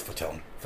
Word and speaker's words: Fatone: Chazes, Joey Fat One. It's Fatone. – Fatone. – It's spelled Fat One.